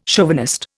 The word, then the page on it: chauvinist: Indian